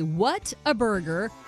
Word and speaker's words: Whataburger: They say, what a burger, Whataburger. It's pronounced Whataburger.